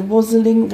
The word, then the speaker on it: wassailing: I pronounce it the wassailing. – Wassailing. – That's more like it.